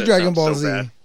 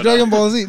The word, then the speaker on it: Dragon Ball Z: Yeah, that is Dragon Ball Z. That's Dragon Ball Z.